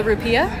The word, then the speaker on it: rupiah: The currency is called the rupiah. – Yeah, the currency is the rupiah. Rupiah. I don't know why I decided to mispronounce it.